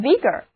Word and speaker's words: vigor: 接下来来看看这个词根 VIG，它也表示活力，那么在它的后面加上 OR 这个名词后缀，就得到了 VIGOR， vigor 这个单词。字母 I 要读作短的 I，而字母组合 OR 要读作短的 A， vigor， vigor 意思就是活力、精力。